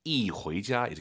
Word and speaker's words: 一: 숫자 1 뒤에 이성이 오면은요, 숫자 1이 사성으로 바뀌어요. 이回家 이렇게 발음하시는 거예요. 이, 이게 아니라 이. 꼭 기억하세요.